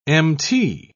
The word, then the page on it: mt: British English